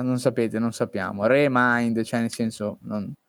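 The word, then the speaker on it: Re:Mind: Kingdom Hearts 3 come si pronuncia? Re Mind? – Quell'R sì. come, è così, Re Mind.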